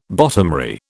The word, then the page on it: bottomry: British English